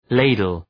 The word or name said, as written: ladle